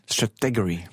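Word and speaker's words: Stratechery: Strategory.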